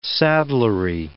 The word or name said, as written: saddlery